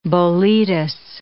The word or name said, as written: boletus